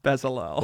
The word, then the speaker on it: Bezalel: Oholiab and Bezalel. – Ooh, good pronunciation. – I've been trying. Oholiab and Bezalel.